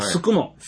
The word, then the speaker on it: 宿毛: あと、あの、宿毛って書いて、すくも。 – すくも。 – って読むんです。あ、確か、狭間寛平さんのね、出身地やったと思うんですけど、はいはい、まあ、すくも市。